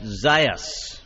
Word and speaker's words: Zaius: I can see the text message screen, and Wade has texted in to say Zeus is pronounced Zaius. – Oh, because it is spelled Z-A-U-S.